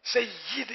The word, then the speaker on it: سید: اس کا بھی تلفظ یا کے نیچے زیر ہے، میت ذرا میت ہی بولتے ہیں، سید یا کے نیچے زیر ہے، سید ہی بولتے ہیں ہمارے عام بول چال